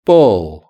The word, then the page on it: ball: British English